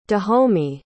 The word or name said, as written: dahomey